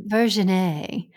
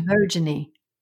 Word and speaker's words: Virginie: It was Virgin A. – Oh, yeah, Virgin A. – Virginie. – Right, because nobody can pronounce it right. So she's like, yeah, it's Virginie, whatever, I don't care.